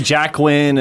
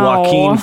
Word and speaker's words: Joaquin: They say Jacqueline and all oh, that. It's no. Joaquin Phoenix. – By the way, anybody listening, you've seen that? It's Joaquin Phoenix.